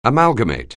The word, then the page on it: amalgamate: anglais